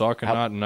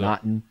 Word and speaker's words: Akhenaten: Akhenaten. I believe that's how most people pronounce Akhenaten. – It. – Akhenaten.